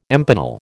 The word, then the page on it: empanel: American English